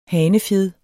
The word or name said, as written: hanefjed